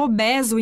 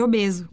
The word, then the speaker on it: obeso: A maior parte deles não sabe que se fala obeso em vez de obeso.